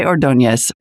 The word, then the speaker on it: Ordonez: Next up on the program is Joshe Ordonez, founder and CEO of AirPals.